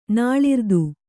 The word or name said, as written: ನಾಳಿರ್ದು